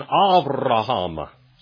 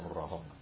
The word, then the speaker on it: Avraham: And then Avraham, say Avraham. You got to roll your R's on that one. Avraham. Okay. Av. It's not Ab. It's Avraham.